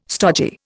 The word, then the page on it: stogy: Indian